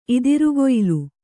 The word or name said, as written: ಇದಿರುಗೊಯಿಲು